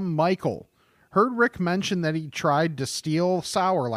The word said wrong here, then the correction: Distill: last week, and I'm still hoping that I'm getting that pronunciation right. It's distill.